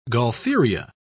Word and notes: gaultheria: British English

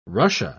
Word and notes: russia: British English

